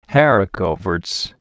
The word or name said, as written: haricot verts